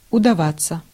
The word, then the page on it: удаваться: /ʊdɐˈvat͡sə/; udavatsja